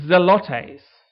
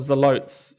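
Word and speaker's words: zelotes (ζηλωτής): Now that word comes from the Greek word zelotes, or zelotes, if you want to pronounce it poorly.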